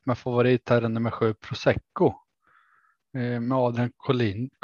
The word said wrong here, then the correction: Collini: Collini. Collini. Collini. ja, det nya uttalet.